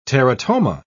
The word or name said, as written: teratoma